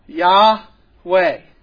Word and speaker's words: Yahweh: It is a four letter word, yod, hey, vod, hey, and those four letters we think would most properly be pronounced Yahweh.